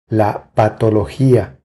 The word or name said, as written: La patologia